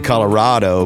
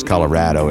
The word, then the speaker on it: Colorado: Some people say Colorado, but I think the official is Colorado. It rhymes with dad, Rado.